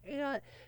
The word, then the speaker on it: Ayotte: I go by, sometimes I was once Stephen Ayotte, but I don't like that name because it rhymes with coyote, with people outside the New England area that don't know that Ayotte is supposed to be pronounced Ayotte, because apparently people have never heard of Senator Kelly unrelated to Stephen Ayotte, but whatever.